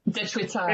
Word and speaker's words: Dechwita: Suzu D1, which is the, I never say this right. Help me. How do I say it? Tewicha. – Dechwita. – There we go.